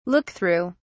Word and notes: look through: British English

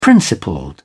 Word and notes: principled: British English

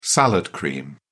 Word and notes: salad cream: British English

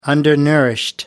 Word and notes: undernourished: American English

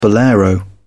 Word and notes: bolero: British English

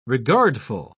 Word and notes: regardful: British English

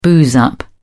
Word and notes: booze up: British English

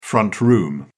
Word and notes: front room: British English

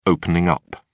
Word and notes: opening up: British English

